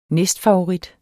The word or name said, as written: næstfavorit